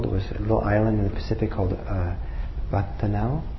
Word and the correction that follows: Vanuatu: Andrea, how do you pronounce it? Vanuatu.